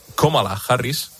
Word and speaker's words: Kamala Harris: La vicepresidenta de Estados Unidos, Kamala Harris,